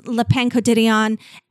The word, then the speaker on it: Le Pain Quotidien: And I'm walking down Ventura and I pass Le Pain Quotidien and there are these two women.